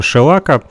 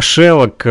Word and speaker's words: шелак: шелака. Э, научили меня, как правильно нужно говорить, потому как э, я раньше говорил «шелок», э, э, но правильно говорить «шелак», да? Да, э, шелок все-таки это термин, э, который устоялся за рубежом.